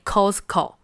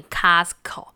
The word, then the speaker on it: Costco: 哎，好事多，我们念中文是没问题，但是我发现到英文有两种发音。第一种有些部分人会念 Costco，就是按照它的发音。那第二种人会念 Costco。